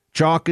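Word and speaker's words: Choq: Chalk is spelled with a Q, by the way, so it's C-H-O-Q.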